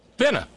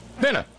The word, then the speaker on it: finna: See, you're still putting that tea in there. You're still putting that tea in there. – What? Would you please pronounce it correctly for me? – Finna. Finna.